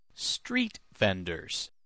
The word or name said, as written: street vendors